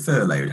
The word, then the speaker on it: furlough: Is it furloughed? How do you pronounce it? – Furlough. – Furloughed.